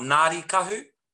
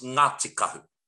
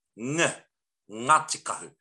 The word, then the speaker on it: Ngāti Kahu: It's not Ngāri Kahu, it's Ngāti Kahu. Ngāti Kahu. And that NG is a ng sound. It's not a silent G with a loud N, n, no.